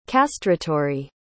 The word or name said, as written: castratory